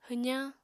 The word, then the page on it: hwzañ: apatani